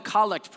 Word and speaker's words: collect: The prayer, the type of prayers is particularly, it's called a collect prayer. It's spelled collect. Think collect call.